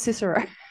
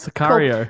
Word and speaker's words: Sicario: I thought it was Cicero. No, that's not how you pronounce it. No, nope. – Sicario. – Cool. Oh, okay. I was calling it Cicero.